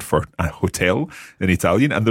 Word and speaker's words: hotel: We say un hotel, mm-hmm. un albergo. – But just watch with hotel in Italian, you don't have to pronounce the H, so it's hotel.